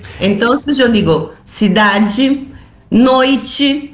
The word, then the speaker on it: cidade: Eu, además de pronunciar G, eu pus la E, eu pronunciei la E como se fosse uma I. – Uma I, claro. – Cidade.